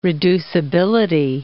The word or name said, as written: reducibility